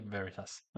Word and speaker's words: Veritas: Det är Veritas. – Ja. – Ja, det var helt rätt.